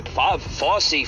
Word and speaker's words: Fauci: Fauci, that's how you say his name. I can never say his name right. Fauci, F-A-U-C-I, right?